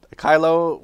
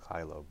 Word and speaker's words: Kylo: Kylo rhymes with silo. Mel Kylo.